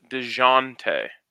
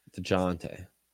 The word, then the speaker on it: Dejounte: I believe it's pronounced DeJounte. – What'd I say? – You're kind of saying a hard J, DeJounte. – DeJounte. – DeJounte. DeJounte. DeJounte. Like Dijon like Mustard.